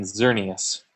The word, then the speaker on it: Xerneas: Yeah, so Evel is the emphasis, and then Xerneas.